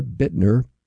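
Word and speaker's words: Büttner: His name is spelled B-U-T-T-N-E-R, Bittner.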